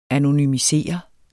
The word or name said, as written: anonymisere